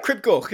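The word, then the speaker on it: Crib Goch: I don't want to pronounce it wrong via Cribgoch. – Yeah, that was all right, Cribgoch, yeah.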